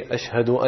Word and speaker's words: Ashhadu: So again, when saying the word Ashhadu, the sheen should be pronounced clearly with a sukun and jazm on it, as well as the ha should be pronounced clearly with a fatha and zabar.